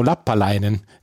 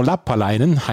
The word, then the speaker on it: Lappalainen: jeder, jeder Name wird oder jeder Nachname wird auf der ersten Silbe betont. Deswegen darf ich jetzt vorlesen. Der, äh, die Bamfang NFCs wurden von Ismo Lappalainen gegründet und Raymond Ebanks.